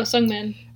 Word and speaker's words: Sungmin: Sungim, is that how I pronounce his name? Sungim? – The dude from Super Junior. – Oh, Sungmin.